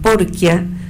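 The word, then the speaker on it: Porchia: Me acordé ahora, mientras lo digo, Porquia, que escribió, se escribe Porcia,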